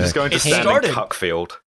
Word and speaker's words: Cuckfield: Like, it's pronounced Cuckfield. – Of course they are. – But it is spelt Cuckfield.